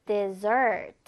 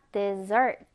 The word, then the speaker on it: dessert: 그래서 dessert. 자, 스펠링이 S를 두번 붙이고요. 강세는 뒷음절을 한다. dessert. 이렇게 발음을 해줍니다.